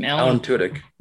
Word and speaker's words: Alan Tudyk: Alan Tudyk is back, and he's going to play K2SO.